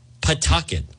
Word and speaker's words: Pawtucket: It's Pawtucket. Rhymes with bucket. Pawtucket.